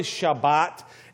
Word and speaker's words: Shabbat: It's it's the word in Hebrew, Shabbat, is how we would pronounce it in kind of a, a tra- an English transliteration of the Hebrew, but it's really Shabbat.